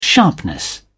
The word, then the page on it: sharpness: British English